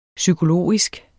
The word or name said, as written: psykologisk